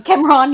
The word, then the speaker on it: Cameron: Cameron, yes, Cameron. I believe that's how it's pronounced.